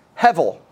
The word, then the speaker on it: hevel: The word vanity is a Hebrew word that is pronounced hevel.